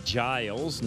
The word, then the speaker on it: Giles: It's Doug Giles, not Guile.